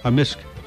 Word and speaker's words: amisk: Yeah, so how do you say beaver? – Beaver? Amisk. – Amisk. – Amisk. – So the K can be silent, but generally it's... – Yeah, amisk.